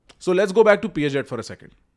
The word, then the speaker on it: Piaget: पियाजेट, PIAGET, पियाजेट।